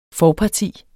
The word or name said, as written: forparti